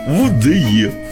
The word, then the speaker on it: УДЕ: Мы просто узнали, что у вас какие-то события грандиозные произошли в вашем УДЕ Неигорского. – УДЕ. Да. Надо так произносить.